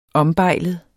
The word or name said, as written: ombejlet